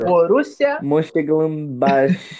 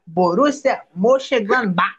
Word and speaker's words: Borussia Mönchengladbach: Borussia. – Mönchengladbach. Mönchengladbach. – Vou ensinar, vou ensinar a pronunciar. Borussia Mönchengladbach.